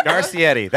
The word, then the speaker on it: Garcetti: Did I say Garcetti wrong? – I don't know. One more time. Pronunciation. Yeah. It was right, but like you said it with like, it like an accent or something.